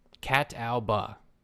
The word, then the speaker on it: Catawba: Catawba, sure. – How does it spell? – C-A-T-A-W-B-A.